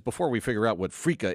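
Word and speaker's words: Freekeh: Did I say it right? Frika? – You sure did.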